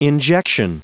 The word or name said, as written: injection